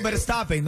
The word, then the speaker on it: Verstappen: Eh, Ganó Verstappen, no se dice Verstappen. ¿eh? Verstappen. Verstappen. – Max Verstappen.